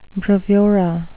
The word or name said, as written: bravura